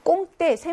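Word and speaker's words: compter: 꽁때, 샘하다, 뭐뭐 할 생각이다 라고 할 때는요, 빼가 발음이 나지 않죠.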